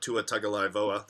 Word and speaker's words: Tua Tagovailoa: You know, obviously none of the Quinn Nordine for, Tua Tagovailoa.